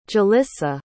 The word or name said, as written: Jelissa